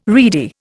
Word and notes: reedy: Indian

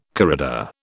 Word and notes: corrida: British English

